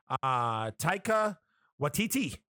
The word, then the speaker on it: Taika Waititi: I always butcher this dude's name, but the director from Thor Ragnarok, Taika Waititi.